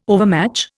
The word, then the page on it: overmatch: Indian